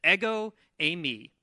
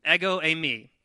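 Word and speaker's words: Ego, a me (ἐγώ εἰμι): He says, "Ego, a me," which is like the waffle and the woman's name if you accent the second syllable. "Ego, a me."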